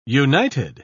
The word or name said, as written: united